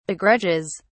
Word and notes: begrudges: British English

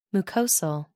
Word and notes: mucosal: American English

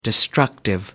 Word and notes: destructive: American English

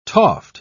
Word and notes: toft: British English

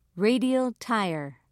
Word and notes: radial tire: British English

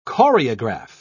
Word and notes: choreograph: British English

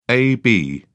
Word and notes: ab: British English

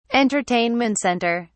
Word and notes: entertainment center: British English

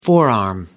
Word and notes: forearm: American English

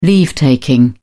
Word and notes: leave-taking: British English

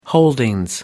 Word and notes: holdings: British English

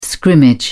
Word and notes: scrimmage: British English

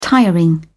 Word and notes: tiring: British English

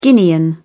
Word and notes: Guinean: British English

